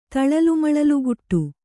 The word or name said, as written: ತಳಲುಮಳಲುಗುಟ್ಟು